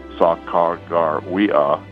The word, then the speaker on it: Sacagawea: and does it phonetically, which is good for us, and says her name is Sa-Ka-Gar-We-Ah, Sa-Ka-Gar-We-Ah, and he says this means bird woman.